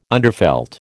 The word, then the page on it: underfelt: American English